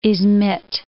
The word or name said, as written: Ismid